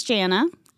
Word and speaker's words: Jannah: Well, my name is Jana. It's like Hannah with a J, so it's spelt a little funky.